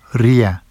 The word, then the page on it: rija: Vafsi